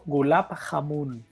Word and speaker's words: Gulab Jamun: Escrito Gulab Jamun, pero se pronuncia Gulab Jamun, que es brutal.